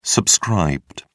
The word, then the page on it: subscribed: British English